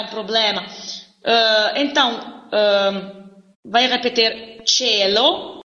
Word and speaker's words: cielo: Agora aqui tem algumas palavras que podem ser escritas com C-I-E, por exemplo, cielo, céu, cielo, se escreve C-I-E-L-O, mas se pronuncia mesmo che. Não tem diferença de pronúncia entre C-E, C-E e C-I-E.